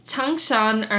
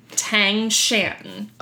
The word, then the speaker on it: Tangshan: It is about the Changshan earthquake. Bam! Motherfucker. – I like that. – Did I not just nail that pronunciation? – Spell it for me. – Tangshan.